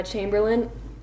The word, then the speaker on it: Chamberlain: Emma Chamberlain. Did I say it right? Chamberlain? Yeah. Chamberlain? Chamberlain. – You said it right the first time.